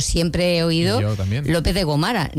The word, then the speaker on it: Gómara: Con el problema de los acentos, hay una avenida en Sevilla, yo conozco la Casa Condal de Soria, que se llama, aquí le dicen, Avenida López de Gomara, y es Avenida de López con acento, Gomara, con acento en la O de Go. Pues nada, no hay tu tía, estoy por llamar a los autobuses, que es donde me entré, y además lo he visto por todas las calles. No, señora, Gómara, no Gomara.